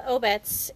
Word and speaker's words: Obec: Well, Obet's looks like it's pronounced Obec, but O-B-E-C, it's the Czech word for community.